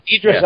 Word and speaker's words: Idris: Eldris? – Idris Elba, yeah.